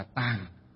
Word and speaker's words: tạng: Thì ở đây chữ tạng ở đây có nghĩa là chữ tạng, còn có một âm nữa đọc là gì, là tàng, đọc là tàng. Chữ tạng còn có một âm nữa đọc là tàng, đều có nghĩa dịch là gì, cái kho, cái nơi chứa đựng.